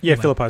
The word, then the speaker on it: Philippi: Am I saying that right? – Philippi is correct.